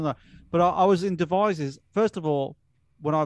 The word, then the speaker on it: Devizes: Have you heard of a place called Devizes? Am I saying it right? – Devizes, yes.